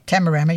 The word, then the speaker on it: Tamarama: At Tamarama. – Oh. – I hope I'm saying that right. – Yeah, Tamarama, yeah.